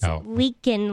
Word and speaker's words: Leakin: It's Leakin, like a leakin sink.